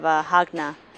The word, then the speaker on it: Jagna: And we found this place in Bohol outside of Hagna called, it's spelled J-A-G-N-A, You can take the bus from Tagbilaran to there.